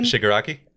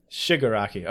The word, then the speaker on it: Shigaraki: How do you pronounce it again? – Shigaraki? – Shigaraki. Okay, sorry. It's Shigaraki.